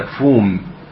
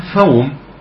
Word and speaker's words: فوم: قول وفومها، الفوم قيل هو الحبوب، أي حب يسمى فوم، وهذا الأشهر في نطقها فوم، وبعض يقول فوم، وقيل هي الحنطة، وقيل هنا الحنطة، وهي مطحون الدقيق، أو هي الدقيق مطحون القمح.